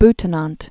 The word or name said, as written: Adolf Friedrich Butenandt